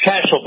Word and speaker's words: Cashel: And it's Cashel, by the way. Rhymes with Dashel, our former wasn't Daschle the wasn't he the minority leader, the majority leader of the Congress? – One of the other.